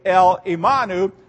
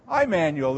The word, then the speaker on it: Emmanuel: And so we would not say El Imanu. We would say Emmanuel. Emmanuel.